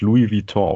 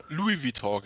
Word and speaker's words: Louis Vuitton: Äh, also wir würden es Louis Vuitton aussprechen. – Louis Vuitton, genau, danke.